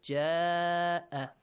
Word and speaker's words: جاء: Hamza and alif mad both are in the same word, in one word. Ja'a. So, six counts, you gotta pronounce the mad, okay? Stretch it for six counts.